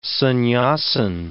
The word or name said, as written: sannyasin